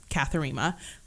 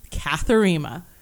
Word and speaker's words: Kathurima: no, it's Kathurima. That's how you say it. – Yeah. – If anyone is out there, Kathurima.